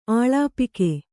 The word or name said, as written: ಅಲ್ಪಾಕ